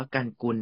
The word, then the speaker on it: GUNKUL: ไรครับสำหรับตัวแรกนะครับบริษัทกันกุลเอนจิเนียริ่งจำกัดมหาชนนะครับตัวย่อของเขาคือ G U N K U L นั่นเองนะครับอ่านว่ากันกุล